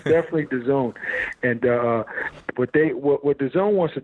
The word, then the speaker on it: DAZN: And thank God, praise God, that, you know, DAZN, that's how they pronounce it, DAZN took a notice to what it is that we do.